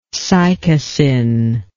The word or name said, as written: cycasin